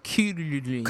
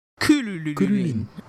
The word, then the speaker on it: Krillin: Anyone who hasn't heard it, go listen to the Japanese pronunciation of krillin. It's like kululin. Krillin. – Kululin.